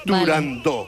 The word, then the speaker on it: Turandot: Para que quede claro, es turandot.